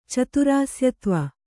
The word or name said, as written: ಚತುರಾಸ್ಯತ್ವ